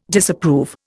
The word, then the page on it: Disapprove: Indian